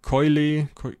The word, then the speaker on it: Koile: Ähm, äh, Koile, Koile, du hattest ja gerade schön ausgesprochen, Koile, Syrien.